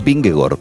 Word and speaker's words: Vingegaard: La doble A en los idiomas escandinavos se pronuncia como una O. Es decir, el ciclista danés Vingegaard es Vingegaard, por raro que suene.